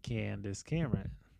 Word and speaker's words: Candace Cameron: She's like, I love Hallmark Christmas movies with Tom Arnold and Candace Cameron.